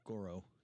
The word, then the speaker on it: Goro: and he is defeated mercifully by Goro. Sorry, did I pronounce that right the first time? – Yeah. – Okay.